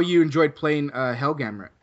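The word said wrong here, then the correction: Hellgrammite: Did I pronounce that right? – No. – Oh God. – It's all right. I didn't know how to pronounce it at first either, and nor did most of my friends, and the general population. I think Hellgramite. – Oh, Hellgramite. Okay. – Yeah. Is how you pronounce that guy.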